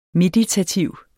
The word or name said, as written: meditativ